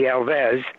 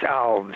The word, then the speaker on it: Alves: It's Alves. If it was Spanish, it would be Alves, but it's Portuguese, so it's Alves.